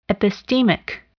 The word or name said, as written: epistemic